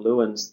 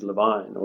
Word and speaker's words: Lewin: We're talking about Lewin's theory of change, but the correct way of pronouncing the name is Levine or Levine.